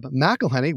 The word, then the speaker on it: McElhenney: The actor is on a sports roll after pulling a Ted Lasso style revamp of Wrexham alongside fellow actor, owner Rob McElhenney, which is how you pronounce it.